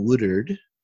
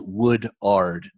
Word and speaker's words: Woodard: by a guy named colin woodard i might be pronouncing that wrong it's spelled woodard w-o-o-d-a-r-d so woodard maybe is, is how i might pronounce it mm.